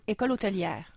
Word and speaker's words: École Hôtelière: Then there's the West Montreal 40 Plus Meetup Group, and they're inviting you to join for a four-course meal at L'École Hôtellerie de Laval. Did I do that well? – I think it's École Hôtelière. – Hôtelière. Okay.